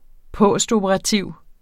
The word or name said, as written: postoperativ